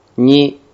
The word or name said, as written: ni